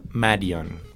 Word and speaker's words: Madeon: Se escribe Madeon y se pronuncia Marion.